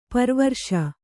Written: ಪರಿವರಿಸು

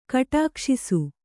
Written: ಕಟಕಸೇಸೆ

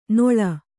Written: ನೋಳ್

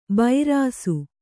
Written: ಬೈರಾಸು

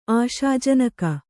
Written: ಆಶಾಜನಕ

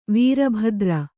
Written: ವೀರಭದ್ರ

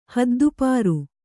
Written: ಹದ್ದುಪಾರು

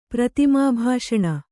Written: ಪ್ರತಿಮಾಭಾಷಣ

